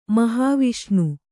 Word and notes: ಮಹಾವಿಷ್ಣು: Kannada

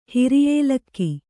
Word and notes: ಹಿರಿಯೇಲಕ್ಕಿ: Kannada

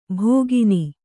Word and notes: ಭೋಗಿನಿ: Kannada

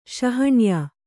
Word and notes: ಶಾಣೆಯ: śaṇeya

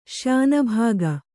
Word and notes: ಶ್ಯಾನುಭೋಗ: kannada

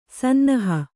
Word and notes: ಸನ್ನಹ: sannaha

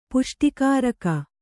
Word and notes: ಪುಷ್ಟಿಕಾರಕ: Kannada

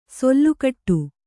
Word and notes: ಸೆಲೆಕೊಡು: sele koḍu

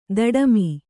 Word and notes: ದುಡುಮಿ: duḍumi; kannada

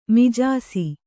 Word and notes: ಮಾಜಿಸು: kannada

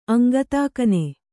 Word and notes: ಅಂಗತಾಕನೆ: Kannada

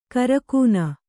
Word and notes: ಕಾರಕೂನ: kārakūna

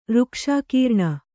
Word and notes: ಋಕ್ಷಾಕೀರ್ಣ: kannada

